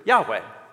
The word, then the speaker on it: Yahweh: which is pronounced in Hebrew, Yahweh.